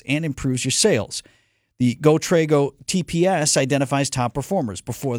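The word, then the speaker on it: GoTrego: GoTrago. Trago is spelled T-R-E-G-O. It's GoTrago.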